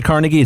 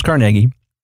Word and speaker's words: Carnegie: People say Carnegie. It's pronounced Carnegie.